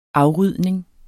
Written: afrydning

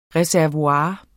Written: reservoir

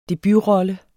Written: debutrolle

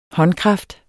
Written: håndkraft

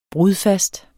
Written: brudfast

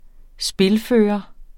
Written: spilfører

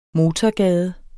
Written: MOTORGADER

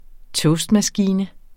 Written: toastmaskine

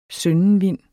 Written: søndenvind

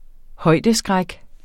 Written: højdeskræk

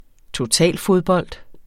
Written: totalfodbold

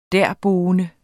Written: derboende

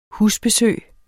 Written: husbesøg

